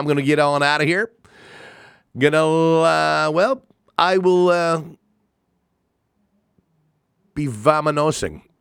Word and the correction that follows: vamoosing: Is that how you say it? Vamanosing? Vamoosing. There you go.